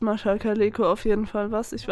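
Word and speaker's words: Mascha Kaléko: Ich habe nur, ähm, da stand noch, dass ähm, spannenderweise äh, 2018 die zwei bestverkauften Lyrikbände von Mascha Kaleko.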